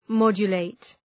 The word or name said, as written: modulate